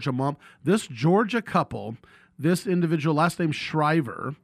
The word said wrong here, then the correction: Shiver: and am I saying it right? Shriver, S-C, yeah, Shiver, S-H-I-V-E-R.